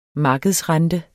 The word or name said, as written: markedsrente